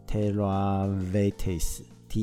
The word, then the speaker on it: Terra Vitis: 然后这一个酒庄还有个特别的呢，就是他们有加入一个神奇的组织，在一九九八年成立的，这个组织叫做怎么念呢？Tehavitis 或这是法文啊，Terra Vitis 跟或者是叫做那个 Terra Vitis，T E R R A V I T I S，这是一个组织，什么样的组织？